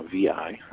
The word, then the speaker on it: Vi: Uh, that was Classic Residence by Hyatt, which went on to become V, uh, VI. Uh, uh, that's often mispronounced, but it's V.